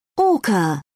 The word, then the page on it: orca: British English